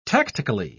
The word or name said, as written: tactically